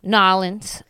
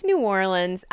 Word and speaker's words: New Orleans: We went to, Danielle and I went to Nolens. – Okay. – Listen. – One way of saying it. – How do you say it? – It's New Orleans.